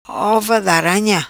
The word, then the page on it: Ova D'Aranha: patua